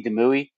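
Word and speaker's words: Demouy: As always, you can hit me up on my personal account, which is at C Demui, C-D-E-M-O-U-Y.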